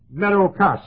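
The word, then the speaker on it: metochos: But the same word occurs in Hebrews 8 times, but this word, metakos, M-E-T, long O, meadow, C-H-O-S, kos, meadow kos.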